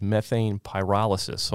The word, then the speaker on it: methane pyrolysis: It mentions methane pyrolysis.